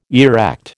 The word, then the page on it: erect: American English